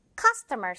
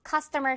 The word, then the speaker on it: customer: 자, call 가면서 customer service. cus 부분의 강세입니다. 갈게요. call customer service.